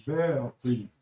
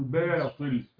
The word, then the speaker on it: باطل: حاجه تقول باطل. ده النطق الصحيح عشان تعود لسانك على التجويد خلاص تقول باطل. اي نعم. كيف تنطق الباء مع الطواء؟